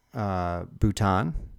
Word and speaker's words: Bhutan: And so this, I think this started with Bhutan.